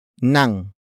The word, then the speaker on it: ng: The next word is nang.